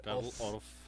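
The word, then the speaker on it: Orff: sería como Carl Off. – Carl Off. Sería como la pronunciación más cercana.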